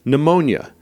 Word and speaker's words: pneumonia: Pneumonia. There's a silent P at the beginning of the word.